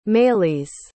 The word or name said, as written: maylise